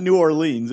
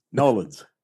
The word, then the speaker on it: New Orleans: It's not New Orleans, it's Nolens. It's one syllable, exactly.